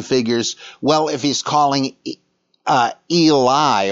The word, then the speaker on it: Eli: But Eloi is Aramaic for my God, but that's not the way it sounds in Hebrew. It's Eli, or Eli, as we would pronounce it.